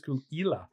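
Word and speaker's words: Caol Ila: And then we went to a distillery shortly after that, and then we saw the Kulila, and I knew, is it Kulila? And they went, no, it's normally pronounced Kulila. And it's actually, and the first name, the first word is pronounced cool. It's C-O-A-L.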